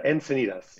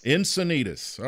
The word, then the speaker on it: Encinitas: It's Encinitas. – Encinitas.